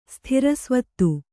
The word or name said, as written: ಸ್ಥಿರಸ್ವತ್ತು